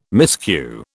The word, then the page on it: miscue: British English